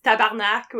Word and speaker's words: tabarnak: Tabernac. – Tabernac. Like, what is that? – It's tabarnak, which is a very French-Canadian swear word, basically.